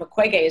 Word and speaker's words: Mukwege: D-E-N-N-Y, but it's actually how Dennis would be pronounced in French. But Mukwege is exactly right.